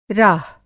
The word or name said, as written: राह